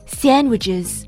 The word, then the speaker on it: sandwiches: purchase 就是 buy 购买， sandwiches 这个单词相信大家都很熟悉，三明治，它的拼写是 sandwiches sandwiches，这个单词里面的 d fa 音一定要轻， sandwiches，大多数人在说 sandwiches 的时候，这个 d 是不发音的，如果要发音，那么应该越轻越好， sandwiches， sandwiches。